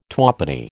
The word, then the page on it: twopenny: American English